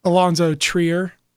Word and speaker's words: Alonzo Trier: Because of the injury to Alonzo Trier.